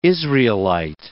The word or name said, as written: Israelite